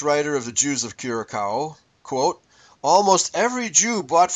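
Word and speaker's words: Curaçao: It's Curacao. That letter is an S. – Curacao. – It's pronounced by like an S, yeah. – Okay. – When so you have a little tail underneath the C.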